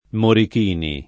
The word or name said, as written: Morichini